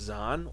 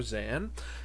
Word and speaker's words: Xan: My name is Zahn, or Zan, X-A-N, however you want to pronounce it.